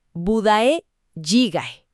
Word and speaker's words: budae jjigae: A ver, la pronunciación correcta, según los documentos que revisamos, es budae jjigae.